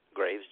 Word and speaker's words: Graves: Corporal Graves. – Corporal Graves, do you have a badge number? – Yeah, 1171. – Spell that last name, please. – Graves, G-R-A-V-E-S.